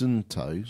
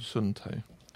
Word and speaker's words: Suunto: So it's Suunto. – Suunto.